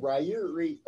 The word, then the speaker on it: Rairia: I said Rairia. Oh. – Rairia? – Rairia. – Rairia. – Rairia. – Rairia. – Damn it. – There you go. Rhymes with Sephira.